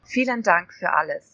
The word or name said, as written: vielen dank fur alles